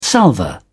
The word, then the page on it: salver: British English